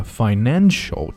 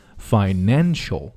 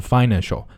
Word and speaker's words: financial: One of the financial, 注意它的重音的位置在后面。 Financial, 不是 financial, financial.